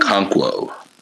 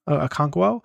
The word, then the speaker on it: Okonkwo: Okonow? I forget how you pronounce his name. – Okonkwo. – Okonkwo.